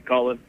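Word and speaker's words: Cú Chulainn: Because I've just recently gotten into reading, and I've read a number of books about Lou Lomfada, obviously Louis the Long Arm, Cahulain, yeah. – otherwise sometimes it's pronounced Cahulain.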